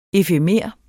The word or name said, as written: EFEMERE